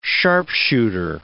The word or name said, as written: sharpshooter